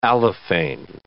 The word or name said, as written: allophane